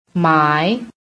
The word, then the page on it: MY: American English